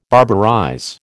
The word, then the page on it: barbarize: American English